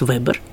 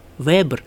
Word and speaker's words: Вебер: Но получается вот что, что мы эту фамилию, немецкую, означающую ткач, как я уже сказала, будем произносить в двух вариантах. Известный такой социолог, человек с европейской славой, Макс Вебер, мы будем произносить твердо, физик Вебер,